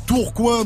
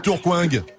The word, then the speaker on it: Tourcoing: Il travaille dans les euh, transports et il nous vient de Tourcoing dans le Nord.